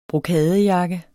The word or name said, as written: brokadejakke